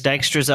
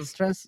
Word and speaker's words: Dijkstra's: Dijkstra's algorithm. – Dijkstra's, yeah.